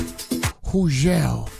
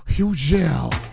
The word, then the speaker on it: Hugel: Hugel. Some people call him Hugel. Hugel with the silent G. He's going to get a show right here on Hot 97. Hugel, silent G.